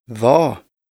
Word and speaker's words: hva: Stumme Konsonanten. – Unnskyld, ledig, hva, det.